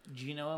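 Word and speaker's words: Juneau: If I had to guess, I would say Juno, Alaska has. – It rhymes with Uno.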